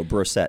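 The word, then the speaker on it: Brossette: No, Brosette.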